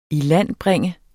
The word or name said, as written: ilandbringe